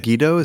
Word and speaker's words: Megiddo: But Josiah disguises himself and then goes and fights Necho at Megiddo. Is that how you say that? – How do you say it? Megiddo is how I say it.